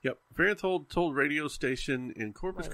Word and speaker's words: Farenthold: Yeah, I looked up how to say his name because I wanted to get it right. Farenthold. Old. Farenthold.